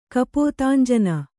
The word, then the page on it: ಕಪೋತಾಂಜನ: kapōtānjana